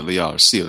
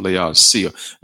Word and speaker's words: Laodicea: That's where you pronounce it, Laodicea, the Church of Laodicea.